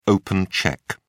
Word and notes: open cheque: British English

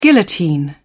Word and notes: guillotine: British English

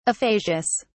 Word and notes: aphasias: British English

